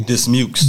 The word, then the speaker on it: Dismukes: Dismukes.